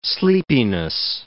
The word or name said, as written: sleepiness